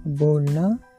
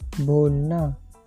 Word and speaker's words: बोलना: Pronunciation this verb बोलना, B O L N A बोलना।